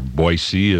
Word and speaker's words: Boise: There are two assignments in Boise, Idaho, or Boise as the locals pronounce it.